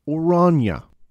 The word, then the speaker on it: Oranje: They would call it the Mauritius River, in honor of Maurice, the Prince of Orange and the leader of the Dutch Republic. – Orange, you glad they didn't give it a different name? – I believe it's more properly pronounced Oranje.